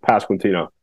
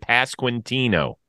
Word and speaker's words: Pasquantino: It's actually a relatively easy name to say. Like, you look at it and you think, "Oh man, this is gonna suck." But it's just Pasquantino, just how it sounds or how it's spelled.